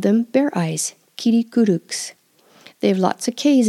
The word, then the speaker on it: Kirikûruks: Ki, ri, ku, ruks. If you can't pronounce that R, use a soft D or even an L. And the K is a soft K again. Kirikuruks. It's almost a G, but not quite.